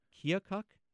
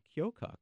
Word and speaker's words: Keokuk: I had people tell me Keokuk and Keokuk. So, and also, and that's not all, there's even more, because in some pronunciations, there's an L sound in the middle, so it becomes Keokuk or Keokuk. Mm-hmm. So that's four.